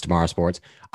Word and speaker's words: TMRW Sports: It's called Tomorrow Sports. It's TMRW Sports, but it's pronounced Tomorrow Sports.